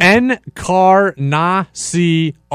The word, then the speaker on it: Encarnacion: I said Encarnacion. Is that not how you say it? – Encarnacion. – Encarnacion.